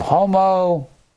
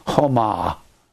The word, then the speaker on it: homo: Confession, homologeo, is a construction of homo. Some people pronounce it homa. I don't care how you pronounce the O, just as long as you pronounce it.